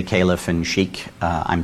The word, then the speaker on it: caliph: Khalifat, in my undoubtedly badly pronounced Arabic, is what we might say in English is caliph. A lot of people will sometimes say khalif.